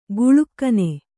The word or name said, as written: ಕೀಳ್ಗಣ್ಣು